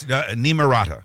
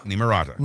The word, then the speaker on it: Nimrata: It's Nimrata. – Nimrata. Is that how you pronounce it? Okay. – Nimrata.